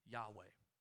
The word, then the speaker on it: Yahweh: Because our English pronunciation of this name is indeed Yahweh.